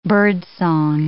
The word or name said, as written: birdsong